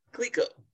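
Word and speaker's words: Clicquot: Clico.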